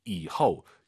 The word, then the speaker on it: 以后: 뒤가 이성이니까 以后我有 그리고 호가 아니라 오유는 어예요. 이허, 이허 이 발음을 꼭 기억하셔야 됩니다.